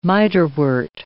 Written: miterwort

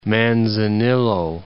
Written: Manzanillo

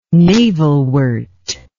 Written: navelwort

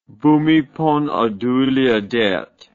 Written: Bhumibol Adulyadej

